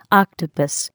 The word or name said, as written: octopus